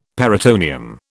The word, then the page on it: peritoneum: British English